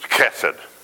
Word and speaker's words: chesed: The Hebrew word used here is the word, and I don't even know how to pronounce it. It's chesed. C-H-E-S-E-D. And if you pronounce it right, that C-H is a sound. So I'm not going to do it anymore. That's it. It's chesed. That's how I'm going to pronounce it from now on. But it has that kind of coffee, phlegmy kind of sound, I guess.